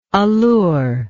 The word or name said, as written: allurer